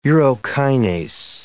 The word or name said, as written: urokinase